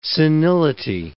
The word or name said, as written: senility